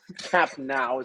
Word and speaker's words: Camp Nou: It's not new, it's N-O-U. So Camp Nou is the correct pronunciation. – You clearly speak no French, pal. N-O-U, it's...